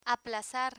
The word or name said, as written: aplazar